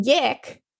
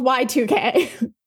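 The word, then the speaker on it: YIIK: And a video game, which I have been pronouncing Yik. It's actually Y2K.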